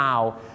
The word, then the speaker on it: hour: hour ถ้าไปออกเสียงว่า 25 hours นี่นะครับผมฝรั่งจะฟังเป็นนกฮูก 25 ตัวนะครับเพราะ owl เนี่ยออกเสียงว่า owl ไม่ใช่ o นะครับ owl นั่นเองครับดังนั้นครับชั่วโมงต้องออกเสียงว่า hour นะครับ hour ค